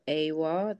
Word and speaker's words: Awuah: Quickly, so on my Twitter, my name is um, Angela underscore Awuah.